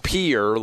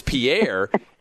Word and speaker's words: Pierre: But everybody was like, no, it's pronounced Pier, like a boat pier. And so I said Pier. And my boys lost it, and said, no, it's Pierre.